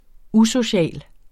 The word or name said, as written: usocial